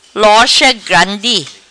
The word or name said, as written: Lorcha Grandi